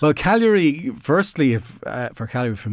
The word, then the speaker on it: Cagliari: Can I, how do you pronounce it? It sounds like Calgary in Canada. – Calgary. – Cagliari. – Cagliari.